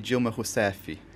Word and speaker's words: Dilma Rousseff: First of all, we all say Dilma Rousseff, but and we know that's not right. Tell us, what is it? How exactly should we pronounce her name? – Yeah, it's actually Dilma Rousseff.